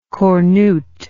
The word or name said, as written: cornute